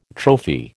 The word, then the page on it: trophy: American English